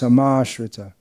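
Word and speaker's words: samāśrita: Sama is pronounced samashrita, means fully taking shelter of.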